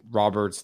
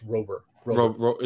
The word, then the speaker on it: Robert: Now that I'm done drafting, Robert's the guy. He's like, he's my guy. – That's right. It's Rober. – Is it Robert? – Rober. Like. Rhymes with over.